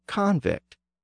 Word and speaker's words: convict: And if the prosecutor is successful, the person becomes a convict, with the stress on the first syllable.